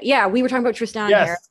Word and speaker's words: Tristan: Tristan, that's right.